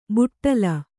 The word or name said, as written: ಬಾಟಲಿ